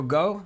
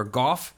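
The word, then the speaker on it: Gough: Uh, late great Michael Go or Goff. Don't know how you pronounce his name. I believe it's Go though.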